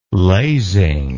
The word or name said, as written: lasing